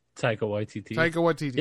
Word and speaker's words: Taika Waititi: And what if, like, how do you say his name again? Takai Waititi? – Taika Waititi. Yeah, he's getting a Star Wars franchise.